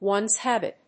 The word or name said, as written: one's habit